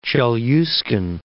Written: Chelyuskin, Cape

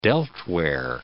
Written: delftware

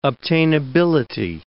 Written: obtainability

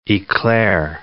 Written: eclair

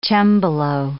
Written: cembalo

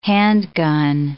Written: handgun

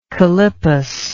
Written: calippus

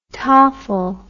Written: tafel

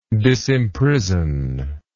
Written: disimprison

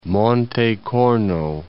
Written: Corno, Monte

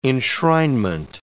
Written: enshrinement

